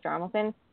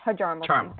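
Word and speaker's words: Hjalmarsson: my sister can't even say Jarmelson. Nicholas Jarmelson screws it up every time. It says Hjarmelson. And I'm like, nope. – Hjarmelson. – Jarmelson.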